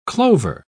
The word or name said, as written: clover